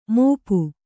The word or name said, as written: ಮುಪ್ಪು